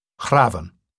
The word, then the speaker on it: hrafn: The word used in Icelandic to this day, hrafn, with the F pronounced as V,